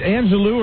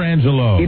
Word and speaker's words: Angelou: And Buzz, is it Angelou or Angelou? – It's spelled Lou, but it's pronounced Low.